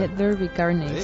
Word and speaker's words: Let There Be Carnage: Let There Be Carnage.